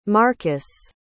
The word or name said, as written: markkas